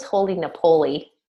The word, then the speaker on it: Holy Napoli: Well, you know, the city of Napoli, is that's how it's pronounced, but it's... The name is designed to for you to say it that way.